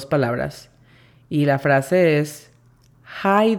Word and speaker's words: hi: La pronunciación fonética se escribiría J-A-I para decir hi, y la pronunciación fonética de there sería D-E-R, con la pronunciación correcta, there.